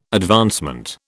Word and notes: advancement: British English

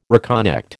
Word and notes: reconnect: American English

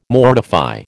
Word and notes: mortify: American English

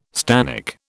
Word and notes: stannic: British English

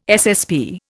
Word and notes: SSP: Indian

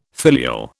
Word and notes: filial: British English

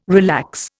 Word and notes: relax: Indian

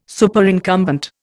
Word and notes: Superincumbent: Indian

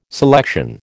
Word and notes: selection: American English

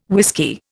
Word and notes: whisky: Indian

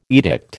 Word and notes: edict: American English

